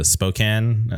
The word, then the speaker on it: Spokane: They're from uh, uh, Spokane. Uh, am I saying that correctly? Yeah, yeah. Okay, yeah. Uh, th- there was this band called um, Spokane, and they were called Made for War.